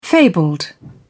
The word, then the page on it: fabled: British English